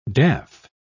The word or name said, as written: daff